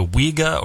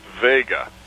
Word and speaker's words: Wega: It's actually spelled W-E-G-A, and it's pronounced Vega.